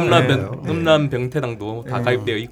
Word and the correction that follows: 음란변태당: 있고 음란병 아니 발음이 안 되나 음란변태당 당비병태당 음란변태당을 이제 들고 마사오닷컴으로 튀어야죠.